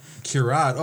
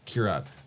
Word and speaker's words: Kirad: Did I say the name wrong? – Kieran. Add D at the end. – Oh, D. A.D. Kirad. – Kirad. Oh, I had an... All right. Kirad.